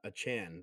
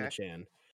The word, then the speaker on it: Achane: Um, so they actually um, De'Von uh, Achane. That's what that's how he wants his name to be pronounced. – De'Von okay. Achane.